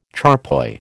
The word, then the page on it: charpoy: American English